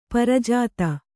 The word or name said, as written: ಪ್ರಜಾತೆ